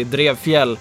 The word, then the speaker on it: Trefiel: It's actually Trefiel. – Trefiel. – That would be the way to pronounce it in Swedish.